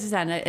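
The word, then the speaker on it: Zenna: It's Zena. – I mispronounced Bruce Coburn. – Yeah. It's pronounced, it is Zena. It's Z-E-N-N-A, Henderson.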